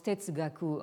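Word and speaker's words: tetsugaku (哲学): donc ce mot se prononce tetsugaku en japonais,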